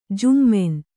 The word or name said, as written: ಝಮ್ಮನೆ